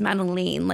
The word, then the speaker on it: Madeline: It's Madeline, like Lane.